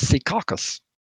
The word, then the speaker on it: Secaucus: And then on June 14th, 2023, I don't know how to pronounce this, S-E-C-A-U-C-U-S. – I would think it's Secaucus.